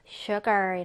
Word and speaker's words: sugar: 자, 지금 발음 기호 보면은 이 발음이 sh 발음이죠. sh. 그래서 sugar 이라고 해요. sugar.